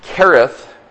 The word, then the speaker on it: Kerith: In fact, the very word Kerith means, the H is silent in that, means to separate or to cut away.